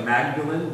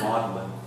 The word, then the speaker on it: Magdalen: It looks like Magdalen, Mary Magdalen, but they pronounce it Magdalen.